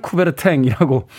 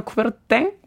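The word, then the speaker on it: Coubertin: 최근에는 쿠베르탱이라고 발음을 하는데 저희 어릴 때는 쿠베르땅이라고 가르쳤던 네, 선생님들도 있었던 기억이 됩니다 아마 이게 프랑스 사람이기 때문에 정확하게 발음하면 쿠베르땡 뭐이 정도로 발음이 나지 않을까 하는 생각이 드는군요.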